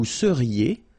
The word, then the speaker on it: seriez: Vous seriez. So here when you have when you've got this EZ at the end, then you get the sound E. Seriez. So vous seriez. Okay. And the last one. Il serait. A-I-E-N-T here.